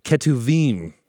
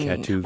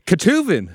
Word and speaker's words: Ketuvim: which is pronounced, everybody with me, Ketuvim. – Mm. – Ketuvim.